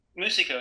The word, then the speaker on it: Moosiko: All right, well, here I am with Musico. Is that how you pronounce it? – Musico, yep. – Musico, yeah. – M-O-O-S-I-K-O.